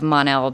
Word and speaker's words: Mon-El: Wilson that we've had on the podcast, and they also said the same thing about the Mon part of Mon-El being pronounced like Mun, like Monday, if you were to say Monday.